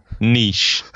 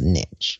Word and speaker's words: niche: It's not at all niche. – Niche. It's pronounced niche.